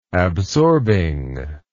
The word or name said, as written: absorbing